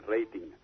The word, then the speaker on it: rating: Supongo que en Venezuela es también la pronunciación inglesa, ¿verdad? – Sí, es también la pronunciación inglesa, sí, rating.